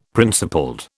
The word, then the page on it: principled: British English